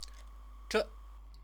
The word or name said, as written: zhe